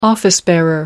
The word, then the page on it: office-bearer: American English